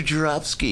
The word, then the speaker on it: Joravsky: Oh, Mr. Joravsky, yes.